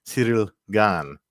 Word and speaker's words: Ciryl Gane: Ciryl Gane, which is supposed to be the appropriate way to pronounce his name.